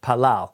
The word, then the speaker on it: palal: To pronounce this in Hebrew, again, from right to left, it's pronounced palal. And this is a word in Hebrew that means pray, palal.